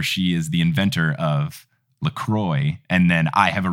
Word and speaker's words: LaCroix: The drink, according to their website, is pronounced LaCroix. The official website for that garbage seltzer water, which tastes like death, is said it's pronounced LaCroix, which is not correct in any language. Either go full French or go full English. Don't call it LaCroix. That's just wrong.